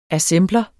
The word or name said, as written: assembler